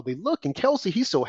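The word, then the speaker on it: Kelce: They will talk about her and her romance of this football player tight end, Khaleesi, I think. Is it pronounced Khaleesi, Andrew, or Khalees? – Kelsey. – Okay, Kelsey.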